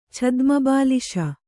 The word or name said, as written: ಛದ್ಮಬಾಲಿಶ